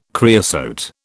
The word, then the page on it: creosote: British English